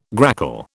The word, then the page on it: grackle: British English